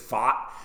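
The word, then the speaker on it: Pfaadt: It's like Fott. It's like a part of my take said it's like how a rich person would say fart. It's Fott.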